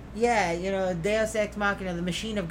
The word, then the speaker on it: Deus: I would have pronounced it Deuce, as in like D-E-U-C-E. – No, see, I always think of it, like Deus Machina.